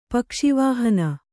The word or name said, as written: ಪಕ್ಷಿವಾಹನ